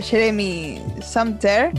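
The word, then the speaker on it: Jeremy Sumpter: Mientras ustedes eh, estaban teniendo problemas con el Inadi, estaba buscando a Jeremy Sumter.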